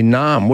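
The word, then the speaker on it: Naarm: That's a good point, because if you took out the first N and the A, you'd have arm, which we pronounce the same way. So they could have just had one A and it'd still be Naam, wouldn't it? – Exactly.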